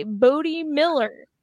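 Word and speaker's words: Bode Miller: A, Bodie Miller.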